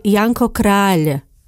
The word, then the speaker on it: Janko Kráľ: Try to say Janko Kráľ. Many Slovaks pronounce the surname of the romantic poet incorrectly. Kráľ is not the same as Kráľ, which means king in Slovak.